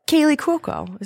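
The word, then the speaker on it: Kaley Cuoco: Kaylee Cuoco. Is that how you say her name from The Big Bang Theory? – Yeah. – Yeah, I think that's how you say it.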